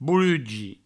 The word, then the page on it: borugi: bourugi